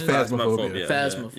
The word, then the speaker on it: Phasmophobia: Phasmophobia or Phantasmophobia, whatever the correct pronunciation is. – Phasmophobia. – Yeah.